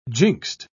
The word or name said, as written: jinxed